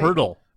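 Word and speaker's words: Pöltl: Purtle. – Uh, Purtle. Purtle. Purtle. – I, got, I swear uh, to you, uh, it's Purtle, even though there's no R.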